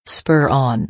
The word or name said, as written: spur on